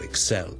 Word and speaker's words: excel: 呢、這個字咧，我哋係一個 verb 嚟嘅，OK， to excel at something，即係喺做某一件事咧做到非常之卓越、好優異嘅，勝過其他人嘅，我哋叫做 to excel at something。個重音咧，其實咧佢係應該放喺 the second syllable 嘅，so excel excel excel。